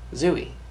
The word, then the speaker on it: Zooey: Zooey. – Zooey? Okay, good. Because that's who they named her after. Okay. I always say Zooey. I don't know why. So it is Zooey? – I mean, I don't know.